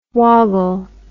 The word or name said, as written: woggle